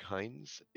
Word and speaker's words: Heinz: It's Rick Hines, H-E-I-N-Z.